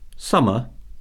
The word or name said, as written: summer